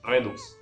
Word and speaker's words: Redux: Redux, no sé cómo se pronuncia. – Redux.